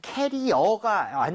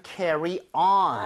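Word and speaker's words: carry-on: 캐리어가 아니라 응? – 실제로는 carry on. – On. – Alright, carry on이 발음이 뭔가 잘못 들어오면서 좀 변하지 않았나 추측해 보고요.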